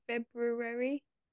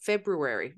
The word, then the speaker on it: February: Well, that's because we don't like to pronounce the R. Like we have a beer. Come over here. I can't hear you. Beer, here. February. They do pronounce the second R.